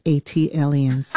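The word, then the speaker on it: ATLiens: Tayari, just as Jill did for Philly, as an unofficial ambassador for Atlanta and Atlians. – That is pronounced Atlians. – Ah, thank you. – Like Atlanta alien. Atlian.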